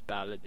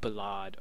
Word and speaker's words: Ballot: His full name is, as I said, Caius Ballot, and it is actually pronounced as Ballot in the game, not Ballad or something else.